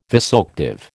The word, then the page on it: vasoactive: American English